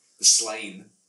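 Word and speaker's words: Sláine: I listened to the Slain one. – Slain, that's the correct pronunciation, according to Patrick Mills, the inventor of the comic.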